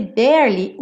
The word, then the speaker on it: barely: Nesse barely, o E do meio não é pronunciado. Então você enrola a língua para o R, bear, e já vai para o li, barely.